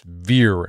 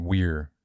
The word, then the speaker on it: vir: It's vir. I mean, you can pronounce it weir too.